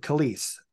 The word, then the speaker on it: Calise: Tim Calise.